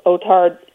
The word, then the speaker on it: OTARD: What it is nominally is a review of the so-called OTARD rules, OTARD, in fact, rhyming with retard, and it stands for over-the-air reception devices.